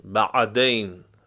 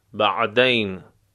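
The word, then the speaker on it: بعدين: در عربی عراقی کلمه بعد از اون به این صورت تلفظ میشه، بعدین، عین از وسط حرف، بعدین.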